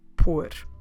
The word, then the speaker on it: pôr: So, on today's episode, we're going to focus on a very important and special verb we have in Portuguese, the verb pôr como se escreve pôr P, O com circunflexo, R. That's right, so this little one has a circumflex accent on the letter O. Why is this?